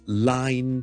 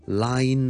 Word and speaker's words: line: 我哋上一集讲过咧，there's a fine line between something 嘅发音，叫大家唔好将个 line 同埋个 nine 咧搞乱。咁呢个当然系 n 音同 l 音嘅分别啦。 line 咧就系 l l l l l l。